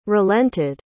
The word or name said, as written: relented